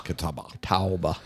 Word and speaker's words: Catawba: How do you pronounce this? Catawba Brewing Company. – Catawba.